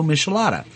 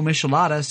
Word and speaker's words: Michelada: Since 2004, when Mambo Seafood filed a trademark application for its Mambo Michelada. And anyways, Anheuser or Anheuser-Busch blocked the application saying that Mambo Michelada sounded too much like Michelob.